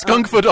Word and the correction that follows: Scunthorpe: on problem. – Scunthorpe on problem. But when you spell Scunthorpe, S-C-U-N-T-H-O-R-P-E.